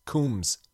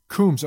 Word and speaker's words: Combs: But I got a phonetic pronunciation on it. It's Combs. – Combs. Okay.